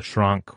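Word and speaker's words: Sranc: For listeners out there who aren't familiar with your work, there's the Shrunk or Shrank. How do you prefer it be pronounced? – Well, I have no preference. I call him Shrank myself.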